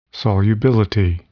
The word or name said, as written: solubility